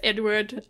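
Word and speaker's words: Eadweard: Yeah, I believe it's just pronounced Edward, but it's not spelled the way Edward usually is.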